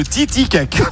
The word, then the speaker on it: Titicaca: Le Titicaca, bonne réponse, qu'il a très bien prononcé.